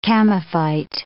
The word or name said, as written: chamaephyte